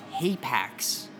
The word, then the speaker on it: hapax: Wait, let me spell, because I mispronounced it. It's Hapax. The Gomagon. – Let's just spell it, please. – So, H-A-P-A-X space L-E-G-O-M-E-N-O-N.